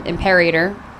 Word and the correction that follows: L'Imperator: I'm sorry, I missed the L. How do you say that, Louis? Go on. – Which horse? – The four. – The four. – L'Imperator. – Yeah, there we go.